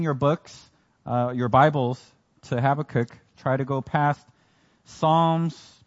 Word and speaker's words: Habakkuk: People have different ways of pronouncing this book, but I pronounce it Habakkuk. So that's what you're going to be hearing all day today.